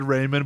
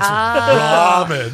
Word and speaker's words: ramen: One of those things where you said Raymond, but then you heard someone else say it, and you're like, oh. – Oh, it's ramen.